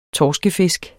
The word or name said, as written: torskefisk